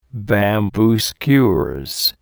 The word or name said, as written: bamboo skewers